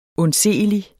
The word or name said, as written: undseelig